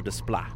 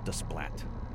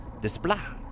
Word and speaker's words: Desplat: On French, proper pronunciation, André Desplat, not Desplat. Desplat, Alexandre Desplat,